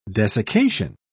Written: desiccation

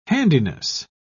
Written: handedness

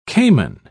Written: cayman

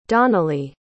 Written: donnelly

